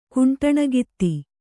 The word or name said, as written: ಕುಂಟಣಗಿತ್ತಿ